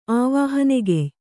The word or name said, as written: ಆವಾಹನೆಗೆಯ್